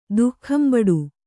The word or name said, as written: ದುಃಖಂಬಡು